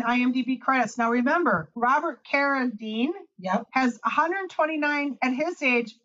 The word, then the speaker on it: Carradine: Carradine.